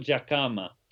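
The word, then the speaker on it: Dhlakama: Maybe talk about what it was like to meet, and correct my pronunciation if I'm wrong, the Lacama? – Okay, yeah. Afonso Jacama. – Jacama.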